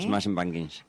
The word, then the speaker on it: Smashing Pumpkins: Bueno, yo nunca he sido muy de Pink Floyd, pero vamos, yo prefiero a Smashing Pumpkins. Lo he dicho bien, ¿eh? – Smashing Pumpkins.